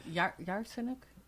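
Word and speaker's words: Yatsenyuk: And it says, get me the document on Artsenuk. Artsenuk was the prime. Now, maybe Hur doesn't know who Artsenuk is. – Yatsenuk. Or something like that. Sorry, I pronounce it Yatsenuk.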